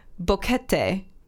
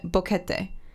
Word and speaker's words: Boquete: I don't know why this was the one of all the words in here, but Boquete, Panama is how you pronounce it. Boquete.